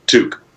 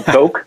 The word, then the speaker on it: toque: And it's like tongue in cheek, but all my American friends say Toke, which yeah. sounds way, way kind of more, I don't know, like like we're being really crazy, you know, drug talk.